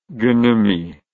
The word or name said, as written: gnomy